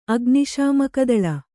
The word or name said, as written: ಅಗ್ನಿಶಾಮಕದಳ